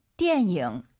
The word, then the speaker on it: 电影: 띠에닝, 니은 발음 들리면 안 돼요. – 电影。